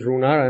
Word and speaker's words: Runar: My name is Runar, and I'm an alcoholic.